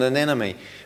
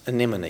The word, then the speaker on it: anemone: And if you see, you know, you've seen maybe pictures of what happens with an anemone when it's touched and it pulls back. Well, it's anemone, isn't it? I was mispronouncing.